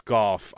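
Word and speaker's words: Gough: I think it's Goff. I'm pretty sure. – I think it's Goff too. It's spelled like Doe, but it's pronounced Goff.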